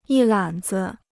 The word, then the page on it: 一揽子: yī lǎn zi